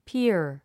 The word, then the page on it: pier: píər; ピア